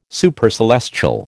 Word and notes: Supercelestial: American English

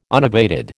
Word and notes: unabated: American English

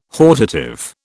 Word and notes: hortative: British English

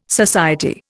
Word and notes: society: Indian

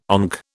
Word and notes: ONC: British English

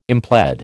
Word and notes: implead: American English

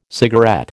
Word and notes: cigarette: American English

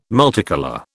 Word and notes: multicolour: British English

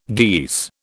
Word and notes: geese: British English

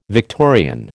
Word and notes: Victorian: American English

